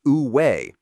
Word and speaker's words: Wu Wei: The Chinese call this state of effortless action Wu Wei, pronounced Wu Wei, and for over 2,000 years, philosophers have wrestled with a fundamental question: how can we achieve spontaneity through effort?